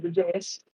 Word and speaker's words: Ageaus: I'm Age, or you may know me as the JS. Yes, the G is silent, but I go by Age pretty much exclusively now online.